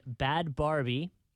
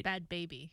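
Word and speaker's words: Bhad Bhabie: So she's out there having this party, and Bad Barbie, who does not even remotely spell it right. – Bad Baby. – Bad Baby. See, I'm saying Barbie. – I know. – Looks like it should be Barbie. – I thought it was Barbie too.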